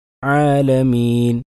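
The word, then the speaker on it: ആലമീൻ (العالمين): ആലമീൻ, അൽഹംദുലില്ലാഹി റബ്ബിൽ ആലമീൻ, അൽആലമീൻ. അവിടെ യാഇനെ മദ്ദാക്കിക്കൊണ്ട് തന്നെയാണ് ഉച്ചരിക്കുന്നത്. അതല്ലാതെ ആലമീൻ അവിടെ ഉന്നത്തിനായി രൂപാന്തരപ്പെടുന്ന അവസ്ഥ ഉണ്ടാകരുത്.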